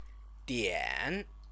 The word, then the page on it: dian: Chinese